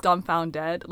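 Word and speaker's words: Dumbfoundead: I always thought it was Dumbfounded. Am I wrong? – It is Dumbfounded. Like, okay. It's spelled like that. I think I just said it really fast.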